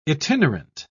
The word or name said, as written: itinerant